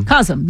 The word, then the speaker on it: COSM: I enjoyed the experience, but I'm really looking forward to seeing sporting events at, am I pronouncing it right? COSM? – COSM, yeah. – C-O-S-M.